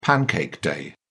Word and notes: pancake day: British English

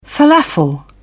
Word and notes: felafel: British English

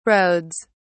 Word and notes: roads: British English

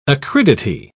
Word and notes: acridity: British English

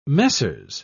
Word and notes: messieurs: British English

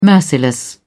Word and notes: merciless: British English

